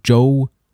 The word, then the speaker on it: Zhou: And then we get about 800 years with the Zhou, it's spelled Z-H-O-U, Zhou dynasty, and that is seen as the beginning of China's history.